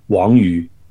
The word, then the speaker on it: Wang Yu: Well, it depends on how you want to pronounce it in Cantonese or Mandarin. Cantonese, yeah, you know, would be Wong Yu, whereas in Mandarin it would be Wong Yu. Well, you know, he's from Taiwan. Oh, well, he's actually from Shanghai. So I think we should go by the Mandarin pronunciation, which of course should be Wang Yu.